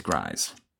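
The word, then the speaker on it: Gries: which was then played by John Rhymes with Fries Grise.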